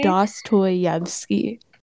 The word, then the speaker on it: Dostoevsky: It's just Dostoevsky.